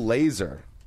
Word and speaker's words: Lazor: All right, we'll start in Miami, where a Dolphins player, this is interesting, says that new Miami's Dolphins offensive coordinator, Bill Lazor, is that how you pronounce his name? – Yeah. Yes.